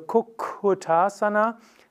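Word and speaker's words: Kukkutasana: Aber im Sanskrit ist es so, ein doppelt geschriebener Konsonant wird länger ausgesprochen. Also Kukutasana macht tatsächlich, dass das Doppel-K etwas länger ist und zwei Ks führen auch dazu, dass die gesamte Silbe lang wird.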